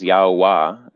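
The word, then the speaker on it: Yahweh: Yeah, so My understanding of Yod Heh Vav Heh, which the most correct pronunciation that I've found is Yao Wah. It's all vowels or vowel sounds or whatnot.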